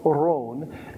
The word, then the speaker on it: Aharon: Because in Hebrew it's actually three syllables. Aharon. And English leaves out the H sound.